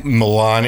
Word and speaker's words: Milan: You, sir, who is from Milan, and it is spelled M-I-L-A-N.